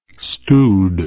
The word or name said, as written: stewed